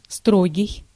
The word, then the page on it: rugged: American English; /ˈrʌɡɪd/